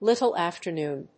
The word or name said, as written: little after noon